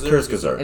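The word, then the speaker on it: Kurzgesagt: I think we're pronouncing their name wrong, but... – It's Kurtzgesagt. – It's Kurtzgesagt? Okay.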